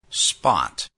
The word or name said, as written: spot